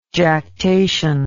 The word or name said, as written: jactation